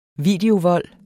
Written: videovold

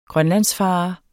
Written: grønlandsfarer

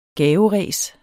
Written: gaveræs

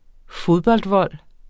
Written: fodboldvold

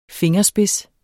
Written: fingerspids

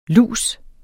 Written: lus

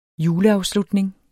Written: JULEAFSLUTNING

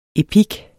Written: EPIKKEN

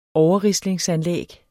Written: overrislingsanlæg

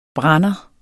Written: BRANDERNES

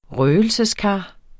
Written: røgelseskar

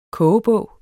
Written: KOGEBOGEN

